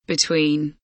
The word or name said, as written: between